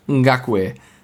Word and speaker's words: Ngakoue: Yannick Ngakoue.